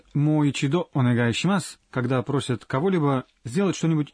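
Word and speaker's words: Мойчидо онегайшимас (もう一度お願いします): В первом слоге в слове «мо» гласное «о» долгое. Давайте потренируем произношение. – «Мойчидо онегайшимас».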